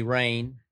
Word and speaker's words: Rayne: His first name is spelled R-A-Y-N-E, Rain. Sometimes I spell it R-E-I-G-N as he reigns over the National Football League.